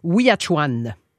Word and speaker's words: Ouiatchouan: Maintenant, il faut que je prononce ça comme du monde, le sentier Ouiatchouan. – Oui, bravo, exactement. Tu le dis très bien.